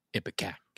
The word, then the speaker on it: Ipecac: Ipecac.